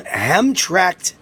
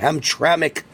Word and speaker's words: Hamtramck: First of all, a Michigan City Council, an all-Muslim Hamtramck, that's how you pronounce it, Hamtramck, Michigan.